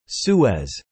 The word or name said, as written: Suez